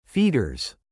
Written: feeders

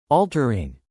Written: altering

